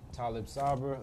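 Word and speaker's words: Taalib Saber: So Talib Sabra, attorney, activist, and filmmaker.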